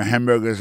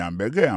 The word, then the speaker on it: hamburger: N'a pas remarqué que dans mot hamburger ça comme t'as dit, il y a trois syllabes là-dedans, côté appuyé puis plus ça c'est sous premier syllabe là.